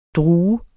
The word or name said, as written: drue